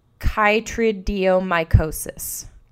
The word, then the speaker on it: chytridiomycosis: So, it is a fungal disease known as chytridiomycosis. Chytridiomycosis, I think. Unless you pronounce the CH as ch. – I pronounced it chytrid, but I also have heard of chytridiomycosis. Chytrid. Basically, it starts with CH, and it affects a lot of amphibians.